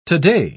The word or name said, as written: today